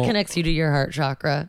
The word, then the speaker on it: chakra: I stay young with the chakra. – The chakras. I guess the appropriate Sanskrit pronunciation is chakra.